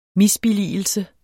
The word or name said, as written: misbilligelse